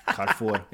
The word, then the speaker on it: Carrefour: I know it's Carrefour. Yeah. Yes. Carrefour. It's from my mom.